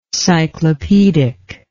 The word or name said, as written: cyclopedic